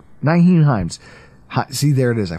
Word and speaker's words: Nyheim: Nyheim Hines. Holy shit, I said his name correctly on the first try.